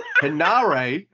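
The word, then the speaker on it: Henare: And Harare? Why did I say it like that? Hanare. It's not hard to say.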